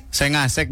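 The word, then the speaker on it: 5àsec: Sek Oh, sengasek bacanya itu. Sengasek. Ah, bacanya gitu. Iya, sengasek.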